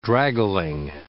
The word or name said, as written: draggling